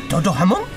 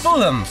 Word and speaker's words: Fulham: ¿Fulham? ¿Toto Hamon? Bueno, como se pronuncie. Cuando empataron pensaba que perdíamos. Oh, yes, Fulham.